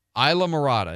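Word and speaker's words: Islamorada: Isla Morada, is that, did I say it right? – Yes.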